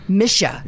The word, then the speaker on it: Mische: Is that the correct way to say your last name? – Mische.